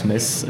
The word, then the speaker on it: Metz: Et, euh, en Metz, qui se prononce Metz avec un S, profite une annonce publique.